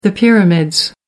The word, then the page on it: pyramids: American English